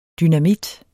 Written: dynamit

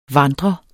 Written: vandrer

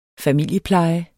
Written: familiepleje

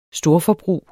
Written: storforbrug

